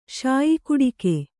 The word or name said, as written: ಶಾಯಿಕುಡಿಕೆ